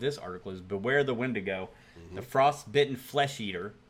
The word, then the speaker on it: Wendigo: That's a really interesting cartoon picture of a Wendigo. Very cool. Winnebago. Rhymes with it.